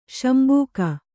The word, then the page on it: ಶಂಬುಕ: kannada